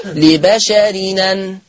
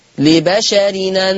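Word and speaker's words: لبشرٍ أن: طبعا أنا كتبت التنوين مجازا حرف نون، لبشر أن تقرأ بالنقل، فلما انقل الهمزة المفتوحة يا إخوة يا أخوات للنون التنوين هتبقى نون التنوين منطوقة نون إيه؟ مفتوحة، فيصير النطق لبشرين لبشرين. يبقى هكذا ما كان لبشر أن تقرأ ما كان لبشرين هكذا، يبقى لبشر أن هتبقى نون مفتوحة فتقرأ لبشر لبشرين.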